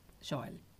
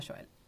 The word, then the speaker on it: Joel: Me pone muy nerviosa que ponga el acento en la E. Joel, no Joel.